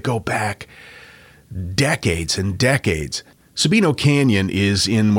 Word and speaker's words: Sabino: I don't know if I'm saying that right. Sabino? – Sabino, yeah. – Sabino. – Sabino Canyon. – Spell it. – S-A-B-I-N-O.